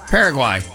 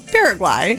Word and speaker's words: Paraguay: Paraguay. – Oh, good job. – Thank you. Paraguay.